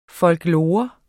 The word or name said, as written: folklore